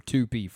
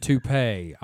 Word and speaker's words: toupee: Horst DeVorze, you know, and Tupi. Hors d'oeuvres and toupee.